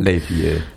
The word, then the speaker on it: Les Vieux: Les Vieux. Am I pronouncing it right? His song about growing. – Les Vieux. Yeah.